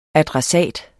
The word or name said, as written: ADRESSATEN